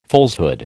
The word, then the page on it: falsehood: American English